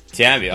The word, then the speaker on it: Tambi: Most people say my name kind of weird, but it's like Bambi with a T. It's just Tambi. – Tambi.